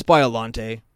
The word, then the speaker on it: Biollante: Yeah, that's Biollante.